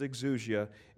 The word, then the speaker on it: exousia: It's pronounced exousia, and it means in the sense of ability,